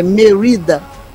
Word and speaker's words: Merida: Tá, o cabelo da Merida, eu fui atrás para ver a pronúncia, pelo menos em inglês, né? É Merida.